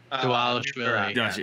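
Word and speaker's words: Dvalishvili: And he got cut from the UFC after losing to Davashvili. Is that how you say his name? Dwalishvili. Yeah. And exactly.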